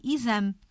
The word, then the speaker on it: exame: Uh, exame é, o som é I, escrevemos com E, mas o som é I, exame. Hum.